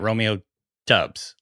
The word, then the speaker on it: Doubs: it had been saying it Romeo Dubs, like a W. – It's D-O-U-B-S is the spelling, D-O-U-B-S.